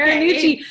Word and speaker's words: Marinucci: Marinucci.